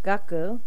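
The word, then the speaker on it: gaku (学): It's pronounced gaku or manabu.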